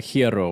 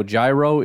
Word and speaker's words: gyro: Yes, it's, again, to my understanding, it's pronounced something like Hierro or whatever, right? That's how it's actually pronounced. And then we butcher it a bunch of different ways, and then we argue about which butchered version is the official butchered version. So it's a whole stupid kind of thing. I will say, though, Gyro is the dumbest.